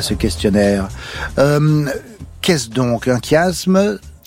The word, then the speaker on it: chiasme: C'est que ça se prononce bien chiasme.